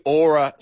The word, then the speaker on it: Oura: Written, Yeah, but it's spelled O-U-R-A, but the wonderful people who have written the ad have said aura as in flora, just in case I don't know how to pronounce it.